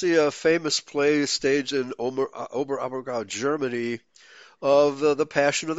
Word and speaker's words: Oberammergau: How do you pronounce it? – Oberammergau. Oberammergau Passion Play.